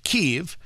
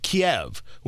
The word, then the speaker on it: Kyiv: And yes, it is Kyiv. That is the Ukrainian pronunciation. We grew up, many of us grew up with Kiev, which would be the Russian pronunciation of the capital city.